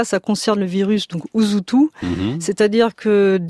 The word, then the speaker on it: Usutu: Donc ça s'écrit Usutu, voilà, U-S-U-T-U, mais ça se prononce Usutu. – Usutu, exactement.